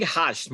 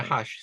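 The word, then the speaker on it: Hajt: How do you pronounce mm-hmm. his last name? – I think Hush might be right. – Yeah, Hush.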